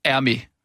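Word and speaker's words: Hermès: Ja, Hermé. Ja, man udtaler ikke H.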